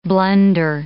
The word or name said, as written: blunder